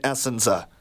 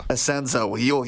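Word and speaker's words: Essensa: Bob Asenza, is that how you pronounce it? Because I always pronounced it Essenza. – Asenza.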